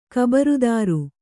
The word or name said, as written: ಖಬರದಾರಿ